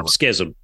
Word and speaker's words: Schism: The only disappointment is there's no Chisholm. That whatever that song, you know, Schism. – Yeah. – Sorry if I even mispronounced it.